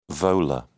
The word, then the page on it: volar: British English